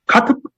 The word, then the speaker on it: Kotb: K-O-T-B. Kotb?